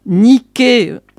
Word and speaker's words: Nike: Bien que Nike, qui se prononce Nike, soit un mot grec signifiant victoire.